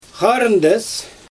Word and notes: xarɯndɛs: Xyzyl

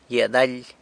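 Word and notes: gyia daly: San Lucas Quiaviní Zapotec

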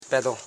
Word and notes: pedon: pe.don; Koro

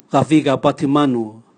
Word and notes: gaviga bwatimanu: Raga

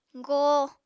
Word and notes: goo: Apatani

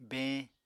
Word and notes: beeñ: Apatani

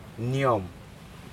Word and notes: niom: Aneityum

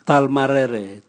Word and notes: talmarere: Raga

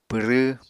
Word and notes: pwrw: Apatani

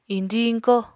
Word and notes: iñdiñ-iñkó: apatani

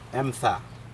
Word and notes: emda: Aneityum

